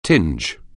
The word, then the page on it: tinge: anglais